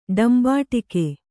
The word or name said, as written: ಡಂಬಾಟಿಕೆ